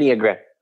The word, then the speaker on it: Enneagram: And then this specific, I still don't know how to say it, Enneagram? Did I say it right? – Enneagram. – Enneagram. – Yeah, that's fine.